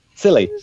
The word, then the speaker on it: Scilly: Yeah, I have to say, I've always wanted to go to the Isles of, I've always pronounced it Scilly because I have no idea. Shilly? Silly? – Silly. Silly.